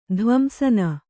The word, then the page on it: ಧ್ವಂಸನ: dhvamsana; Kannada